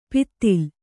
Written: ಪಿತ್ತಿಲ್

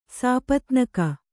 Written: ಸಪತ್ನೀಕ

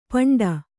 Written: ಪಿಂಡಿ

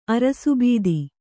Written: ಅರಸುಬೀದಿ

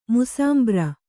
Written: ಮುಸಾಂಬ್ರ

